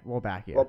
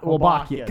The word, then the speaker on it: Wolbachia: And also, oh god, what is Wolbachia. – Wolbachia.